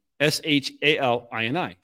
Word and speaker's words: Shalini: So it looks like Shalini, S H A L I N I, Shalini, but it's Shalini, like Melanie.